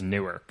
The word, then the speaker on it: Newark: I thought it was New York, but I was like, oh, no, you're right, it is Newark, so.